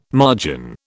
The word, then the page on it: margin: British English